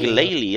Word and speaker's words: Glalie: And you get a Pokemon which is a Glalie, if I'm pronouncing it right. – Glalie? – Glalie, that would be it, the ice type.